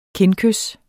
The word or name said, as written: kindkys